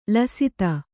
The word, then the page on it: ಲಸಿತ: kannada